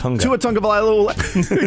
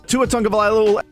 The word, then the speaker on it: Tua Tagovailoa: By the way, Tua explaining his name is pronounced Tagovailoa. Tua Tagovailoa. Exactly. Actually, you know what? This is actually Tua saying how his name is pronounced. Tua Tagovailoa.